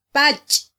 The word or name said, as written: pach